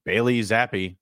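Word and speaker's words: Bailey Zappe: Bailey Zappi, that's how they pronounced it.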